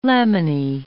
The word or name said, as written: laminae